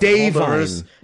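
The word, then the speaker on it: Da'Vine: Davine, which I cannot tell you how angry I am. Yeah, that's how it's pronounced.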